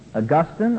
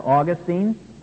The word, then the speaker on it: Augustine: Augustine or Augustine,